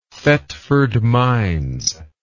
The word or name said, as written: Thetford Mines